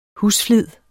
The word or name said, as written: husflid